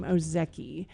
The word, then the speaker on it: Ozeki: And this author is Ruth Ozeki. I think actually she pronounces her name Ozeki. It's O-Z-E-K-I, Japanese.